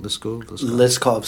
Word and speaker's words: Liskov: Is it Liskov? Is that how you say it? Liskov substitutability principle. – Correct.